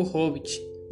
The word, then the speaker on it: Hobbit: Agora falarei um pouco sobre a resenha do filme O Hobbit, pronunciado como Bob. É, a Desolação de Smaug.